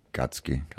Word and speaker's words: Gacky: Chloe Häusler, Günther Gatzki. Also geschrieben wie mit G-A-C-K-Y. Aber Günther mit TH. – Ja, Gatzki ausgesprochen, ne? – Genau. – Wie Gacki eigentlich geschrieben. – Ja, aber ausgesprochen Gatzki.